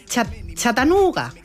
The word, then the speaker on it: Chattanooga: Nos vamos a Chattanooga.